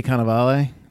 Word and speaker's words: Cannavale: well, I'm a big fan of Bobby Cannavale.